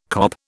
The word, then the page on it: kop: British English